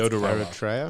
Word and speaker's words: Aotearoa: it's like History of Aotearoa, which I can't pronounce. – Aotearoa.